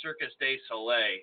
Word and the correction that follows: Cirque du Soleil: Did I say that wrong? Because you're giving me that look. Did I? – The Circus de Soleil. – What is it? – Cirque du Soleil.